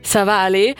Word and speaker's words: Ça va aller: Vorher hören wir Ça va aller, was...